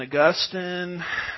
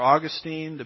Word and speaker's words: Augustine: But Origen and Augustine, or Augustine, depends on which syllable gets the emphasis there, they would take that prophecy and say, oh, that doesn't mean that.